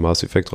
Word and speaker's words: Mass Effect: Mass Effects. Wie spricht man das aus? – Mass Effect. – Ohne S. Effekt, ohne S, genau.